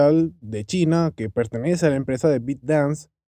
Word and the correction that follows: ByteDance: o Bit, sí, así, ByteDance, mejor dicho, yo creo que se pronuncia así, ByteDance, está siendo bloqueada en muchos países del mundo.